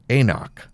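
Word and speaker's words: AnCnoc: Oh, it has there right on there, pronounced Eknock. Why do they have all the extra letters in this? My goodness. Something you shouldn't mess around with pronunciations is something that you drink that contains alcohol. It shouldn't make it more complicated on us. Just write it how it is. But I guess that's a Gaelic name, home of the Eknock, from the Knockdo Distillery, under old Black Knock Hill.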